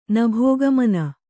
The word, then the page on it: ನಭೋಗಮನ: Kannada